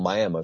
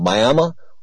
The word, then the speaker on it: Miami: They call it Miami. It's called Miami is the way it's spelled. But Miami, Oklahoma.